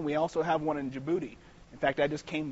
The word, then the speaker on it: Djibouti: And here's Djibouti. It rhymes with certain things, like your booty.